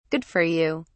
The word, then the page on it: good for you: British English